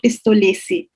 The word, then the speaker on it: Pistolesi: So Mr. Claudio Pistolesi, am I saying it correctly? – Yes, you did.